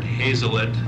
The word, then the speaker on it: Hazelett: My name is Stafford Hazlett. The last name is spelled H-A-Z-E-L-E-T-T.